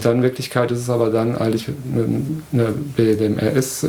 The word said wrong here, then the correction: BDSM: ich kann es nicht mal aussprechen, ups. – BDSM. – BDSM, genau. Szene.